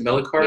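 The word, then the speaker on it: Melichar: Am I pronouncing that right? Is it Melikar? – Yes, you are.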